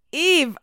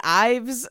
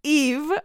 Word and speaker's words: IVE: Eve. – And in my head, I was like, don't say it wrong, because I was saying Ives. Eve, okay, like Eve Saint Laurent, I should remember that.